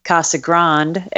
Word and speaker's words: Casa Grande: Uh, looking forward to a little sun and fun down in uh, Casa Grande, Arizona. – There you go. She's the first to pronounce it officially correctly.